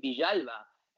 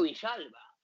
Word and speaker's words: Villalba: He's Argentine born, and he pronounces his name Villalba. And I broadcasted it every single goal that he scored, Tito Villalba.